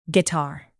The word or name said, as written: guitar